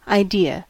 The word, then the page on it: idea: /aɪ.ˈdi.ə/; /ʌɪˈdɪə/; /aɪˈdiɚ/; /aɪ.ˈdɪə/